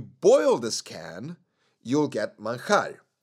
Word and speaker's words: manjar: Dulce de leche manjar. M-A-N-J. Because in Spanish, we pronounce J as A-R. Manjar.